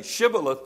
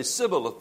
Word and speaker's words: Shibboleth: You remember the word Shibboleth in the Old Testament, where they got the river there and they were trying to make everyone say Shibboleth because certain people could not say it. They would say Sibboleth. They could not pronounce it correctly and they would know that they were the enemy.